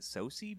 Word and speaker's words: Sosie: Is it Sosie Bacon? Is that how you say it? Who's Kevin Bacon's daughter. – Yeah, which I did not learn until afterward.